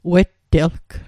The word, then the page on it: wettelg: Mi'gmaq